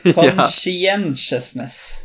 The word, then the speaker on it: conscientiousness: Jeg, for tidligere hadde jeg bare tre stavelser, og så gikk jeg opp til fire, men så er det fem. Conscientiousness.